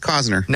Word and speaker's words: Costner: No, I'm pretty sure it's Costner. – No, we asked him. We literally asked him. He said it's Costner.